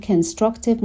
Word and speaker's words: Constructive: Constructive Conflict به تلفظ دقت کنین، هر دو با CO شروع میشن ولی در ابتدای Constructive ما صدای شوا رو داریم ولی در ابتدای کانفلیکت صدای آ رو داریم.